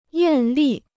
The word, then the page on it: 艳丽: yàn lì